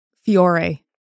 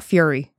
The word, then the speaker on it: Fiore: Some people pronounce it as Fiore, I think. It's F-I-O-R-E. – Oh, so it's not Fiore. – No, it's not Fury.